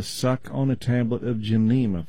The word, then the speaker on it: Gymnema: That is spelled G-Y-M-N-E-M-A, Gymnema.